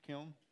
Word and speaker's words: Kiln: Kiln? Nikhil. You don't say the N, right? The N, silence, Mississippi.